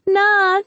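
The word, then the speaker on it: niam: Sometimes she would pound at the door like a caged animal and shout, 'Nah, nah, nah, nah.' Now I know that means daddy in Hmong. In fact, Leah was probably saying Nia, pronounced Na, which means mother.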